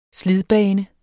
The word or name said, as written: slidbane